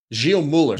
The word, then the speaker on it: Gilles Muller: Guy Muller, hope I said that right. – Gilles Muller.